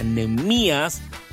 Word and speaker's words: Nehemías: Nehemías, Lucas, tiene acento en la I. – Pues es que yo lo digo como si tuviera acento en la E. – Ok, pero no es, está mal el acento en la E. Se pronuncia Nehemías, por ende requiere un acento en la I, latina.